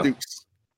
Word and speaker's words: Dux: Dukes. It's spelled D-U-X.